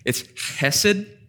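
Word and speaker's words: chesed (חסד): And it's interesting, this phrase, steadfast love, it's in the Hebrew, I'm going to try, it's chesed. So if you pronounce it that way, you need to cough on the person in front of you, otherwise you're probably not saying it right.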